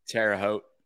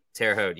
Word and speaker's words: Terre Haute: How you say it? Terre Haute? – Terre Haute. – Terre Haute. Yeah. Yeah.